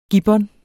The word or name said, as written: gibbon